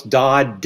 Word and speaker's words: Dodd: Look, just go to Amazon and type in Brian Dodd, and Brian is spelled with an I, and with my Southern accent, nobody can understand my last name. So it's Dodd, D as in David, O, D as in David, D as in David, okay?